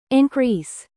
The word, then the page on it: increase: American English; verb